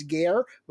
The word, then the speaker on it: GARE: So first, tell us what is Race Forward, and then what is this program that I guess we refer to as GARE? Would that be the right pronunciation of the acronym? – That would be it, not to be confused with a French train station.